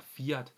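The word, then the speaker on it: Kvyat: Og selvfølgelig var der Ricardo, men jeg synes også, at man bør fremhæve Toro Rosso, både Jean-Éric Vergne, og så også Daniel, nu skal jeg passe på, hvordan jeg udtalte det, men det udtales åbenbart Fiat, sådan som jeg har hørt det.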